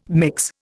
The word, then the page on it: mix: Indian